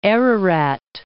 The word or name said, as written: Ararat